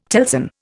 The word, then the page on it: telson: Indian